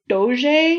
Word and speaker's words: doge: which was a republic who had an elected doge, or doge, if you want to get memeified. – It was a what? – So the title of the leader of Venice was called the Doge, but it's spelled D-O-G-E.